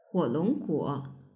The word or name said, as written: huolongguo